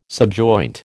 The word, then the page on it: subjoint: American English